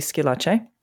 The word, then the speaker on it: Schillace: Well, I am, so my name is Brandi Scilace, and that is spelled S-C-H-I-L-L-A-C-E.